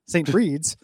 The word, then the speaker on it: St. Bride's: It was developed by St. Bride's, pronounced Breed, St. Breed's, a.k.a.